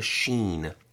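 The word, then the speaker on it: shin: It's called a sheen. It's pronounced sh. It's the SH sound.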